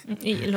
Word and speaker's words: Egil: Uh, och uh, ja, jag kör ju på Sven-uttalet från och med uttalsomgången. jag kommer inte säga Egil Skallagrim, mm, som jag Egil. – Egil låter jättebra ja.